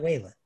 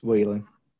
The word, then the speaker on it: Wallen: It's got the two L's. How is that Wallen? – It's Wallen. – What? You pronounced his own name wrong.